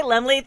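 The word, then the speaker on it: Laemmle: Coming around the corner, September 30th, playing through October 6th in Los Angeles at the, Lemley Theaters. Lemley Theaters in Santa Monica. – Yes, it's a cute little theater. I've been there many times, never knew how to say it until right now, honey.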